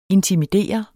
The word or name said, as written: INTIMIDER